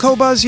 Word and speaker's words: Qobuz: Have you heard about Cobuzz yet? Have you heard the buzz about Cobuzz? – I've just heard about Cobuzz recently. – Cobuzz is the new high-res music streaming and download service. It's spelled Q-O-B-U-Z.